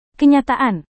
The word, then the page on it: kenyataan: indonesian